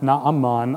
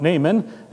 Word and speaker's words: Naaman: Naaman, although his name would have been pronounced Naaman, I'm going to say Naaman for general reasons.